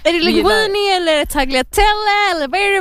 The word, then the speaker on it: tagliatelle: Alltså det heter faktiskt tagliatelle. – Men det är med G. Oh ju. God. Det är ju med G. – Ja, men G uttalas inte i italienska där jag kommer ifrån. Så tagliatelle. Anyway.